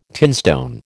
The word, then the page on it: tinstone: American English